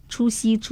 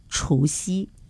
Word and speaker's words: 除夕: 另外呢，那个除夕这词语呢，那个音啊，声调有点问题，除夕要读除夕，除夕。 – 对了，是二声的哈。